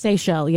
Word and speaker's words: Seychelles: Have you ever heard of the, I believe it's pronounced, it pronounced Seychelles? – Seychelles, yeah. – The Seychelles, yeah. The island, islands? – S-E-Y-C-H-E-L-L-E.